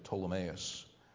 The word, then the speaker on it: Ptolemais: And when we had finished our course from Tyre, we came to Ptolemais, so that P is silent there, Ptolemais, and saluted the brethren and abode with them one day.